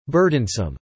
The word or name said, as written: burdensome